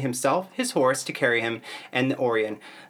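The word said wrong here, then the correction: Auryn: the childlike Empress's necklace of interwoven snakes to protect him. – Or the Orin, I mispronounced it.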